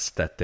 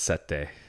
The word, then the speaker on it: sette: It's not sette. It's sette.